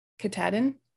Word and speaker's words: Katahdin: Katahdin. – Katahdin.